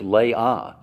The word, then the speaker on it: Leah: And that's Leah, or Leia, whichever, you know, so now we have to say Leia because Princess Leia, you know, it's just there. But actually, the pronunciation of it is Leah, so that is correct.